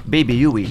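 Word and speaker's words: Baby Huey: Comment on prononce pour vous? – Bébé Yui. – Yui, Yui. Voilà, c'est ça qu'on trouve sur les compiles.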